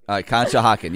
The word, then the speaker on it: Conshohocken: It's in beautiful Conshohocken, Pennsylvania. – Oh, boy. – That, what? I don't know. Is that how you say it? Did I say that right, Terrence? Okay. Conshohocken, yeah.